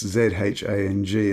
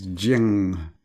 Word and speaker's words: Zhang: It's Zhang, and I think it's Jiang, according to Wikipedia.